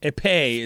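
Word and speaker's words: épée: Women's team EP quarterfinals, which is fencing. – Epee is how it's pronounced.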